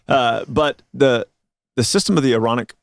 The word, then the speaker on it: Aaronic: But what people do is people think that the Aaronic priesthood was Aaronic. – Yeah. – Not ironic. – I just think that's a fun way to say it. – It is. You said it right.